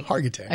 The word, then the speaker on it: Hargitay: Mariska Hart, I never can say her name. Mariska Hargitay? – There you go. Hargitay.